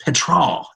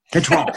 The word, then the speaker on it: Petras: And I think Spencer, the quarterback, I think it's pronounced Petrol, isn't it? Petrol.